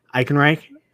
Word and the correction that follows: Ehrenreich: Is that right? Alden Ehrenreich. – Ehrenreich. – It's technically Ehrenreich, but Ehrenreich works.